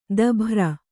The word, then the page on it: ದಭ್ರ: dabhra